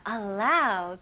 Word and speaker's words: allow: allow 그리고 permit입니다. 차이점이 뭘까요? 네, 일단은 첫 번째 살펴볼게요. allow, 이거는 일반적으로 허락하다라는 뜻을 가지고 있고요. 발음 연습해 볼게요. allow, allow. 그래서 A로 시작하지만 이 발음이 나와야 됩니다. allow.